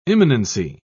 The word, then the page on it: imminency: British English